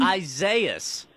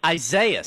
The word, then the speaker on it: Isaias: Me just sitting in the dark, courtesy of Hurricane, how did we say we're pronouncing this storm again? – Isaias. Isaias. – Yeah, nailed it.